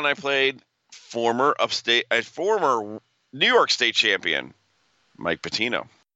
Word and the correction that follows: Pantino: Mike Pantino. – Pantino. – Not Patino.